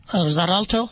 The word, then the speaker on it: Xarelto: The ER put me on, here we go, Xarelto? – Xarelto? – There we go. See, thank you. X-A-R-E-L-T-O. – I know. They use weird spelling. It's spelled X-E-L-T-O, but it's pronounced as Xarelto.